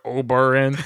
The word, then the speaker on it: Ubering: Say Uber, Uber, Uber, Ubering. It's a three syllable word, Ubering.